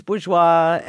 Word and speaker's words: Bourgeois: Is that how you say her name? – Yeah, Louise Bourgeois.